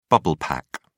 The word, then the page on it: bubble pack: British English